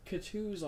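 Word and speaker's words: Coutee: Kutu's alright, isn't he? Is that how you say it? – Kiki Kutu.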